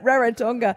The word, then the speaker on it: Rarotonga: There were a lot of tourists that were off to Rarotonga. Is that how you say it? – Rarotonga. – Yeah.